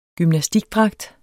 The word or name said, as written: gymnastikdragt